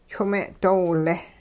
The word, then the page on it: chwing-me'doowile: hupa